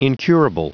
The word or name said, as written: incurable